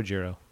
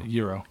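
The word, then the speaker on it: gyro: How do you say Euro? Do you say Euro or Giro? – Euro.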